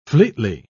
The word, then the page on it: fleetly: British English